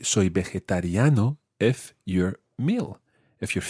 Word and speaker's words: vegetariano: And that phrase is, soy vegetariano. – Soy vegetariano. – Muy bien. It's quite tricky to say. Try that syllable by syllable. Ve. – Ve. – Je. – Je. – Ta. – Ta. – Riano.